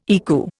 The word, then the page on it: ECU: Indian